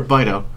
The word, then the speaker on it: Bitto: B-I-T-T-O. Is it Bitto or Bito? – I'd say Bitto.